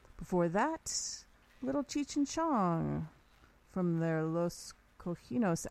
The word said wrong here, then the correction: Los Cochinos: album. Am I saying it right? Los Cochinos, a couple bits, the Strawberry Revival Festival, and Don't Bug Me, some 70s classics right there.